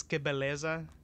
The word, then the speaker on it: Que Beleza: Jesus Christ, I butchered the fuck out of that. Whatever that yeah, one is. Um, um, it's Que Beleza and O Caminho do Bem.